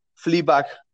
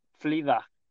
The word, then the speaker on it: Fleabag: Fleabag. Fleabag. – ¿Cómo se escribe? – F-L-E-A-B-A-G.